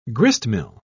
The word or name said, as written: gristmill